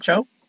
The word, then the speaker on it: Cho: But anyhow, Mr. Michael Cho, is that how you pronounce it? Cho? – Cho's fine.